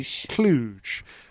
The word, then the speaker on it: Cluj: Cluj.